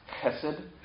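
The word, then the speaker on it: chesed (חסד): It's chesed. H-E-S-E-D is an easy way to pronounce it, but it's really a K-H, like a chesed.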